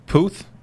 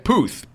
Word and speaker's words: Puth: Charlie Puth? – Is that how you say it? – I think so. – Okay, Puth, P-U-T-H.